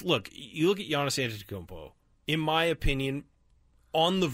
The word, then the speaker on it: Antetokounmpo: Giannis Antetokounmpo. – Say it again. – Giannis Antetokounmpo. – Giannis Antetokounmpo. – Antetokounmpo. There you go.